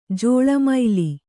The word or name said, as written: ಜಲಮಲಿ